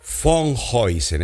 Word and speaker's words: Van Heusen: Antes que nada, vamos a escuchar este tema, a este tema de que la música es de Jimmy von Heusen, que se escribe Van Heusen Ustedes saben que si uno va a Holanda, eh, en Holanda, en el Van Gogh que decimos todos, se dice Von Gogh. Una vez me corrigieron, así que vamos a pronunciarlo bien. Von Huysen es